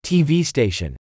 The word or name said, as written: TV station